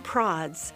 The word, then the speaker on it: Prods: And it's easy to remember, Prime Prods rhymes with classy broads.